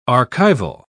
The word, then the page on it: archival: British English